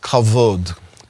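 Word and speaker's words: kavod (כבוד): Today, we're going to be talking about the word kavod.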